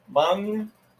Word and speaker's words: mung: And today we're going to talk about I really don't know how to pronounce this. – I think it's Mung. – Mung, M-U-N-G, bean.